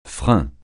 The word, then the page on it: frein: francais